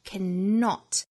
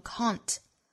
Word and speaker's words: cannot: 这个词音音发成 cannot。我们注意后面 not 的发音 not。 Not, 前面的 C-A-N 是弱读的，发成 can, can, cannot, cannot. 那这个词呢，我们也可以有另外一种读法，就是缩约在一起读成 can't, C-A-N, 撇 T, 读成 can't.